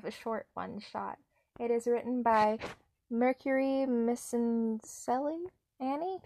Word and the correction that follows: Miscellany: It's spelled Mercury Misencelli. Oh, it's Mercury Miscellany. I got it in the end.